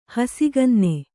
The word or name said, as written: ಹಸಿಗನ್ನೆ